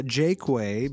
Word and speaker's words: Jaqua: My whole life I've said Jakeway because that's how my family pronounces it. J-A-Q-U-A. And there's a road on the way from Boyne City to East Jordan called Jakeway Road. It's spelled J-A-Q-U-A-Y.